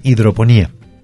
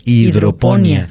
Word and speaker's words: hidroponía: Entonces, la palabra que es correcta en este momento es hidroponía, con acento en la I.